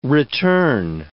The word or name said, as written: return